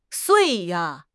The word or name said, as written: sui4